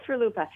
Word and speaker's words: RLUIPA: It sounds like rhymes with Chalupa. Okay, go ahead. – Of course, RLUIPA.